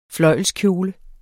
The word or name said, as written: fløjlskjole